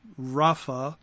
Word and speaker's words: Rapha: The other more used word for giants is a word that would be pronounced Rapha, and it's 7497.